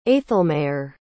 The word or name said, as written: aethelmaer